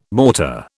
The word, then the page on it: mortar: British English